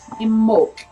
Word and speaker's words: Imbolc: Now it's spelled I-M-B-O-L-C, but it is said in the Gaelic way, Imbolc, Imbolc.